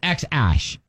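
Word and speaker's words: X Æ: I mean, it's just X, the letter X, and then the A-E is like pronounced Ash. – Okay, so X-Ash is what they're going to call him.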